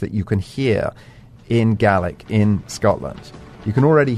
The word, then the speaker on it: Gaelic: Yes, Gaelic. That's how it's pronounced, not Gaelic. Gaelic is Irish.